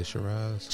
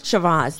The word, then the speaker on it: Shiraz: S H I A I R A Z Shazar. – To me, that would almost say Shiraz. – Shiraz, thank Shiraz.